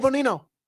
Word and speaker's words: Bonino: Instead of Bonino, I said Gino.